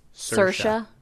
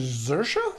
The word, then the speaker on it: Saoirse: It's Saoirse. – Like inertia. – I pronounce it with a Z. – You didn't watch the Saturday Night Live with her in it, apparently. – She says Saoirse? – Saoirse, like inertia.